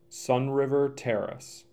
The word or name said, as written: Sun River Terrace